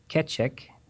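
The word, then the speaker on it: Keccak: And the algorithm is called KECCHAK, and that's spelled K-E-C-C-A-K, K-E-C-C-A-K.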